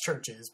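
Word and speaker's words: CHVRCHES: I've been listening to Churches lately, which is stylized as Churches with a V instead of a U. It's pronounced Churches, but it's spelled with a V.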